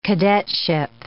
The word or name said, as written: cadetship